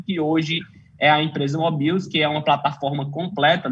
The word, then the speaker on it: Mobills: Hoje a gente está para Android, iOS e também para o computador, é só entrar no site, procurar por Mobills, Mobiles, escreve mais ou menos qualquer coisa que você vai encontrar aí, mas a pronúncia certa é Mobills.